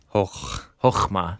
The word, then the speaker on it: chokmah: Yeah, so the Hebrew word for wisdom is the word chokmah. You kind of have to clear your throat a little bit in that second syllable, chokmah.